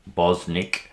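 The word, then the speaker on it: Bosnich: However, I tend to agree with a couple of the experts, one in particular, Mark Bosnick, who, I think is the proper pronunciation.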